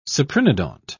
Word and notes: cyprinodont: British English

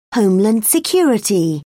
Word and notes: homeland security: British English

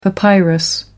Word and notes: papyrus: American English

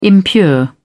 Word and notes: impure: British English

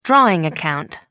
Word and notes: drawing account: American English

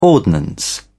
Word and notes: ordnance: British English

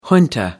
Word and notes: junta: American English

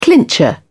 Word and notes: clincher: British English

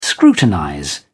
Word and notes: scrutinize: British English

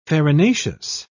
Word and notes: farinaceous: British English

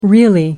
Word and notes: really: American English

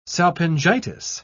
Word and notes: salpingitis: British English

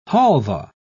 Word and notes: halvah: British English

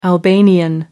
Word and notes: albanian: American English

